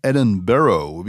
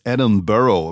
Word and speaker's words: Edinburgh: Ich hätte es jetzt fast wieder Edinburgh ausgesprochen, aber es heißt Edinburgh, wie ihr mich dann gleich verbessert habt auf Instagram, ihr Schluris, Edinburgh.